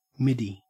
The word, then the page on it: middy: Australian English